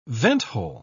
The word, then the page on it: venthole: British English